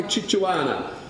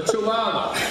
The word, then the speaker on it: chihuahua: Kimberly said that she would fight like a Chichuana. Fight like a Chichuana. A Chichuana. I stand corrected on the pronunciation of the dog. She would fight like a chichihuahua. Like a chichihuahua. For a chihuahua.